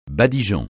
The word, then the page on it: badigeon: francais